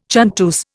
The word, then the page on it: chanteuse: Indian